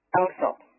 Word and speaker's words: Alsop: Alsop.